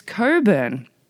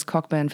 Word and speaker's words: Cockburn: But Footloose is the movie playing at the Whistler Olympic Plaza tomorrow and Wednesday night with live music from Bruce Coburn on Thursday at Whistler Olympic Plaza. I know, I thought it was Cockburn for the longest time, but apparently that's not how you pronounce his name.